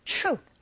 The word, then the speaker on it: true: true가 아니라 t 사운드와 r 사운드가 이렇게 맞물려 있는 경우에는 이런 소리로 발음을 하게 되고, 또 그렇게 들리게 되거든요. 그래서 발음하실 때 true가 아니라 true.